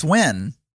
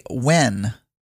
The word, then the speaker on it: Nguyen: Oh, it's Wen. – But I feel like I know him. Like if I go to Google Images and search for him. – You say Wen. That's how you pronounce the last name.